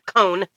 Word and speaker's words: Cohn: Mark Cohen. Or, I'm sorry, Cohn. I would want to pronounce it wrong. Mark Cohn.